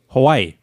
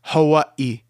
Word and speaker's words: Hawaii: Hawaii. – That's not how you pronounce that. It's Hawaii. There's a little thing there.